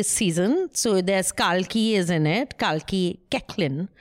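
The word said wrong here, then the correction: Koechlin: Kekla. That's how she pronounces it.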